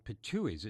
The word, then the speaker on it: pitohuis: They are jay-like birds called pitohuis. It's spelled P-I-T-O-H-U-I, the plural, adding an S, sounding all the world like a cartoon depiction of someone spitting out something, so the word seems appropriate.